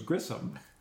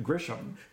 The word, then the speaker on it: Grissom: Anyway, but I forgot that his name was Grissom, and I pronounced it Grisham.